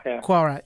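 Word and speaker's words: Quaoar: So in terms that we can understand, you found a ring around this planet Quawar. Is that how you pronounce it? – Quawar.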